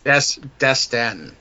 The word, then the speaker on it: Dastan: Now, I kept asking Chris, is his name Dustin or Dastin? Because it just... – Dastan. – Dastan. – D-A-S-T-A-N.